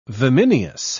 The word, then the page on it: vimineous: British English